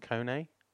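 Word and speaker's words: Koné: Is his name, is it pronounced Kone? – Yeah.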